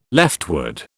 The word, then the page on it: leftward: British English